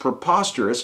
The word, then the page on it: preposterous: American English